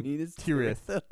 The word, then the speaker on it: Minas Tirith: Minas Arnor was renamed Minas Tirithil Tower. Is that, I said that wrong? – Minas Tirith. – Tirith. I'm trying my best.